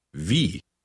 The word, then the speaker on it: Wie: Wie. – Note how the letter W is pronounced in German. It has a soft V sound.